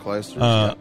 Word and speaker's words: Clijsters: Tom Brady and Kim Clijsters. Is that how you say her name? – Yeah, Clijsters.